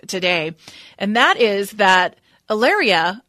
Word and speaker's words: Hilaria: He also got married not too long ago, remarried to a woman named Ilaria, spelled Hilaria, but pronounced Ilaria.